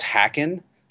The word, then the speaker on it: Haken: at any rate, I remember Richard saying, I think in the letters pages, or it might have been online, that that you pronounce Haken like the town in New Jersey. And I immediately knew what that meant because there's a town in New Jersey called Hackensack.